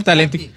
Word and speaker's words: Talenti: Sí, señor, Talenti. Pero para que la gente sepa, sí se escribe Talenti, no es como que no lo está dando en michoacano. – Talenti, italiano, esté parlando italiano.